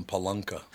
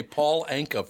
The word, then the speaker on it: Paul Anka: Who was it that couldn't say his name, so he called him Palunka? He couldn't say Paul Anka for some reason.